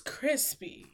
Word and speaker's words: Chrispy: And my name is Crispy, and that's spelled C-H-R-I-S-P-Y, also known as Lando Calrissian, also known as Offset Smirk.